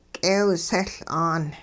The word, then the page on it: k'eewseL'aan: hupa